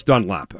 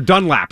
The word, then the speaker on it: Dunlap: He appreciates this opportunity, and that's why I'm jacked up for both him and Carlos Dunlap, right? Or Dunlap, excuse me.